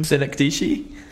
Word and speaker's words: Synecdoche: It's a bit like more miniature Kaufman film where Synecdoche, New York, where he tries to build New York inside a theater. – Wait, say the word again. – Synecdoche.